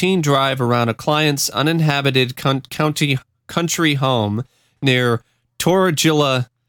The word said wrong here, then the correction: Torriglia: Torriglia, Italy.